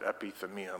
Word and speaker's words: epithymia: And so epithymia or epithymel. I'm going to pronounce it epithymia most of the time.